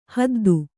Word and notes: ಹೊದ್ದು: kannada

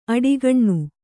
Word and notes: ಆಢಕೀನ: āḍhakīna

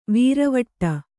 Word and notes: ವೀರವಟ್ಟ: vīravaṭṭa

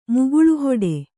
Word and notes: ಮುಗುಳುಹೊಡೆ: Kannada

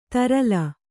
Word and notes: ತರಲ: tarala

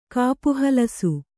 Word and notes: ಕಾಪುಹಲಸು: kannada

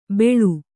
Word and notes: ಬೆಳು: beḷu

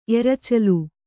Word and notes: ಎರಚಲು: Kannada

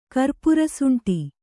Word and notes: ಕರ್ಪುರಸುಂಟಿ: kannada; karpurasuṇṭi

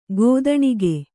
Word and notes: ಕಥಾನಕ: kathānaka

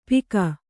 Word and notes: ಪಿಕ: Kannada

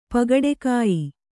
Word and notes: ಪಗಡೆಕಾಯಿ: Kannada

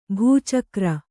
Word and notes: ಭಚಕ್ರ: bha cakra

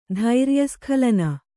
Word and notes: ಧೈರ್ಯಸ್ಖಲನ: Kannada

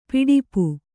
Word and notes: ಪಿಡಿಪು: kannada

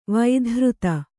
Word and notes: ವೈಧೃತ: vaidhřta

